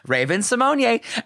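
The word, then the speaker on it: Raven-Symoné: Isn't it Raven Simone? – Okay. On All Stars 2, when Raven Simonier is a guest judge, RuPaul's like, Raven Simonier. And she's like, yeah, that's how you say it.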